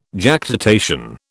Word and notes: Jactitation: British English

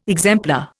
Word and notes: exemplar: Indian

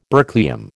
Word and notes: berkelium: American English

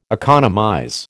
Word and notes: economize: American English

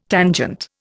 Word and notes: tangent: Indian

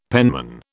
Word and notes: penman: British English

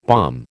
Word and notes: balm: American English

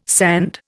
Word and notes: sand: Indian

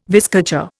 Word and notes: vizcacha: Indian

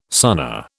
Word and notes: Sunna: British English

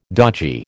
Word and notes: dodgy: American English

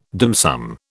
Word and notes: dim sum: British English

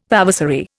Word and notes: vavasory: Indian

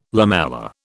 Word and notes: lamella: British English

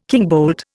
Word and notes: kingbolt: Indian

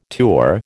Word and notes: tour: American English

